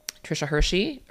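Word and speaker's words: Tricia Hersey: You know, Trisha Hersey. Yes, that's how you say her name.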